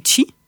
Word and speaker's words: qi: on appelle le chi. Donc en japonais ça s'écrit KI, en chinois ça s'écrit QI, comme dans Qigong par exemple, qui est une pratique qui est très connue.